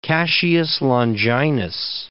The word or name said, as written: Cassius Longinus